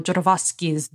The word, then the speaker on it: Jodorowsky's: Speaking of Dune, Mark says, again, up for auction later this month, Alejandro Jodorowsky's Dune. Did I say that right? – Mm-hmm. – Cool.